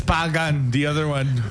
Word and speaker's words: Pakkan: I need a pagan chewagan. One who will pick the money, bro. – And it's spelled P-A-K-K-A-N, but again, this is where the K sounds kind of like a G.